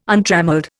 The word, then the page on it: untrammelled: Indian